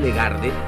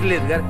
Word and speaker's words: Ledgard: Me dicen Legarde, pero no sé lo que es. Insisto que es Ledgar y sale al revés. Es Ledgar, es fácil, es palabra llana, pero lo pronuncian como le da la gana. Se dice Ledgar y no Ledgarde, pero yo sé que nunca lo voy a lograr de.